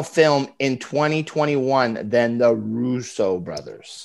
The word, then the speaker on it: Russo: I said the Razzo brothers. Yeah. – So it's Russo. Yeah. It's how it's pronounced.